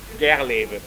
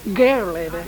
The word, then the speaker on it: Gerleve: Si è fatto monaco benedettino nell'abbazia di Gerleve in Westfalia. Se pronuncio male, io tedesco, insomma, io siamo proprio, poco consanguinei. – Gerleve. Gerleve, sì.